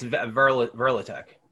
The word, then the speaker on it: Kvelertak: they're in my list for the finals and I still can't pronounce them right. I think. Kvelertak? – Oh, it's Verlatek.